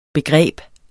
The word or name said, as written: begreb